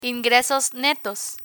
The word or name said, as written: ingresos netos